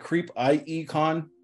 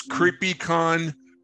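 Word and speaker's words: CreepIE Con: Yeah, I look forward to seeing you guys again at is it Creep IE Con? – Yeah. – Is that how you pronounce it? – It's Creepy Con, but it means it's Creepy Inland Empire Con.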